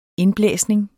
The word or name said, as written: indblæsning